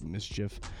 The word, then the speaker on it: MSCHF: It's MSCHF. I believe it's pronounced like mischief.